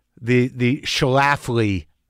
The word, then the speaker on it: Schlafly: But when you have control, how much control did you have over this, the Schlafly project? – You said it right.